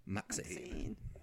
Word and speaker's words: MaXXXine: XXX. Maxine. that's how I'm going to pronounce it when we got all the tickets and stuff.